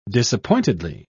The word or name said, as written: disappointedly